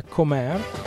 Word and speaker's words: Comber: Un trio di Glasgow, appunto, anche loro, si scrive Comber, ma da quel che ho capito si pronuncia Comber, non so la B dove finisce.